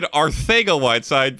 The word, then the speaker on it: Arcega-Whiteside: And yes, I said Arcega-Whiteside because apparently he says, from his Spanish origin, I would assume, that is the proper pronunciation of his name.